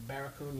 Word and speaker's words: Barracoon: how'd you pronounce that word? Barracoon? – Barracoon, yeah, so it's like.